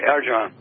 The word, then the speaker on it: Arjan: Just like the way you say it. It's the correct way, Arjan.